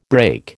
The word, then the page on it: brake: American English